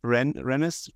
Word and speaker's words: Rennes: How do you pronounce it again? Rennes? – Yeah.